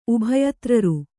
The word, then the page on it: ಉಭಯತ್ರರು: Kannada